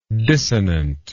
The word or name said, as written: dissonantly